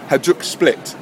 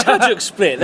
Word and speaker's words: Hajduk Split: It's Hajduk Split. They're a well-known club. It's not a good pronunciation. – All right. – So it's an okay pronunciation.